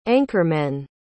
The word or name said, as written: anchormen